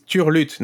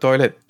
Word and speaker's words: turlute: Yeah, but it's turlute, not toilet. Turlute. Well, it sounds close. – It sounds close, but it's not exactly not at all the same.